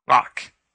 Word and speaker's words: Lock: Lock. L-O-C-K.